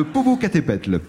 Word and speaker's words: Popocatépetl: J'ai parfois du mal à prononcer les noms alsaciens, mais vous, alors, vous prononcez les noms mexicains, alors là, les doigts dans le nez. Le Popocatépetl.